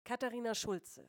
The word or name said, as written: Katharina Schulze